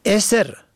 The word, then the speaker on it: اثر: اثر الف سه ر به فتح اول و دوم، نشانه اثر الف سه ر به کسر اول و سکون دوم، عقب آخر به کسر دوم، پسین معنا می دهد.